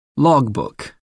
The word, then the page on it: logbook: American English